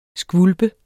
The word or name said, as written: skvulp